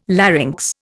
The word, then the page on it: larynx: Indian